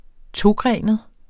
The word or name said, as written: togrenet